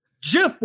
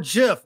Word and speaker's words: GIF: Gentlemen, GIF or JIF? Where are we? – You almost had my respect. With the proper pronunciation the first time. He just had to go back down for the GIF. He just had to. – I have learned now that it is GIF. At that time, I honestly did not know the pronunciation.